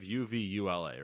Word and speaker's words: uvula: Yeah, but it's like it's almost like you make the second U an E. – It's because like phonetically, it's uvula. So uvula.